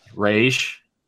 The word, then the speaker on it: Ra's al Ghul: What is the proper pronunciation? – Ra's al Ghul. – Because you hear different things. You hear Ra's, Ra'sh, and there's one more.